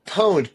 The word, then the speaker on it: Pwned: And it's Pwned. Sorry. Said it wrong. – Pwned. PWN. It's a gaming term.